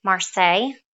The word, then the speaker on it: Marseille: So that was in France, in Marseille. If I'm saying it wrong, you tell me, y'all. – Nope, I got that one. – That was perfect.